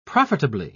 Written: profitably

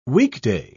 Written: weekday

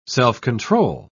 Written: self-control

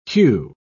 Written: hew

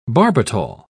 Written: barbital

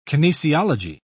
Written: kinesiology

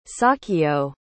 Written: sahkyo

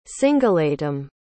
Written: singillatim

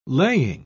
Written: laying

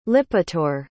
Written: lipitor

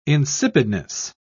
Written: insipidness

